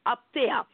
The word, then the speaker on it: apostasia: And so when we look at the word apostasy, this is from the Greek word apteia, right? I'll spell it for you. A-P-O-S-T-A-S-I-A, which means that there would be a defiance of an established system or authority.